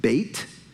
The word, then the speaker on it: Beth: Beth, or better pronounced Bait, means house.